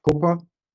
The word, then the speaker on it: COPPA: So there's the Children's Online Privacy Protection Act, often called the COPPA, similar to how we pronounce HIPAA.